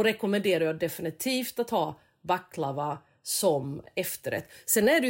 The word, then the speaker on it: baklava: Jag säger baklava, vissa säger baklava och vissa säger baklava. Alltså förstår du att det finns beroende på var du kommer ifrån. Så om någon nu säger så här, när de lyssnar på oss, varför uttalar hon, varför har hon betoningen där, så är det ju för att jag betonade på makedonskt vis.